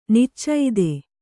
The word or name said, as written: ನಿಚ್ಚೈದೆ